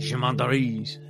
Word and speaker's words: gendarmes: Gendarmes.